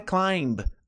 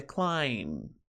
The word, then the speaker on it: climb: Não é climb. O B não é pronunciado, é climb. O B é mudo.